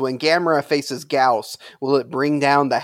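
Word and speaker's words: Gyaos: Is it Gauss? – It's Gauss. – Gauss. – Gauss. – Yeah, Gauss.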